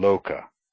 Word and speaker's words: loka: O, pronounced O, is like the O in opal, as in loka, world.